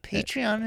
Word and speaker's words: Patreon: Patreon is...